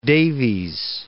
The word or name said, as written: Davies